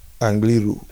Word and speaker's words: Angliru: How do you how do you pronounce it? Angliru. Angliru, sorry not kangaroo as I like to call it.